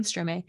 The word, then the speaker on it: Stromae: Stromae. – I'm sorry. Mm-hmm. Stromae.